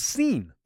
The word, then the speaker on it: scene: The S is silent. – Because a C-E-N-E, that would still pronounce scene.